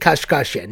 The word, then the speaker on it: Kashkashian: Right now, I am going to play the song of the day, and what I've picked out for you today is Kim Kashkashian No, I said it right. Don't do that to me. I know what you're looking at. I said it Kashkashian. No, you heard it right.